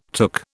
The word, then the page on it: took: British English